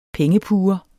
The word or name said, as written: pengepuger